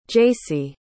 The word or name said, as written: jacy